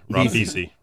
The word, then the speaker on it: Ribisi: Ribisi. Ribisi.